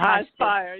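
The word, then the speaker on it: Highspire: Did I say that wrong? High Spire or High Spire? You had it.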